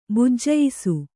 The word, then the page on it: ಭಜಿಯಿಸು: bhajiyisu